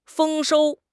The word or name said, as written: 丰收